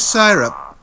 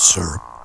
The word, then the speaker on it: syrup: Mr. Syrup. – That's Syrup. – Mr. Syrup. Sorry, it's the... – them damn cigarettes, I'm sorry. – Dear, you fellows across the pond have so many different pronunciations.